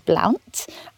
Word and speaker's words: Blount: And one of my books was on Bessie Blount, and my PhD thesis was on the Blount family, or the Blunt family. And the pronunciation really is an individual thing. Some modern Blounts or Blunts will swear that it's one way to pronounce it, and some will swear it's the other way. Even Tudor documents show that there are different pronunciations based on spelling.